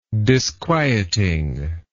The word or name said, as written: disquieting